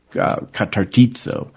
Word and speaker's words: katartizo: Catartizo, and Merriman calls it Operation Restoration. And restoration is catartizo. I put the D in there to remind me that there's a soft D in that pronunciation. K-A-T-A-R-T-I-Z-O, for the ones not looking at the slide this morning on the internet.